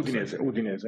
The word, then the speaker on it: Udinese: I say Udinese, but I think you said it differently. I think I'm saying it wrong. How's the correct? – No, Udinese. That's correct.